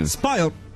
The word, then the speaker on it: spoiled: Aqui, spoiled. Você engole o finalzinho. – Spoiled.